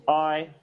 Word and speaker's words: Ahi: He's called I, that's spelled A-H-I.